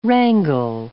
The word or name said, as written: wrangle